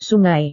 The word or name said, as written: sungai